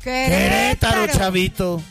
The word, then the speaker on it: Querétaro: Dice, me perdona la pronunciación, que te taro. Querétaro. – Querétaro. – ¿Cómo se dice? – Querétaro, chavito.